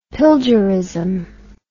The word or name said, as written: pilgerism